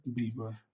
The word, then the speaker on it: Bieber: Did I say his name right? Beaver or Bieber? – Bieber.